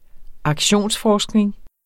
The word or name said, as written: aktionsforskning